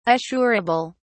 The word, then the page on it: assurable: British English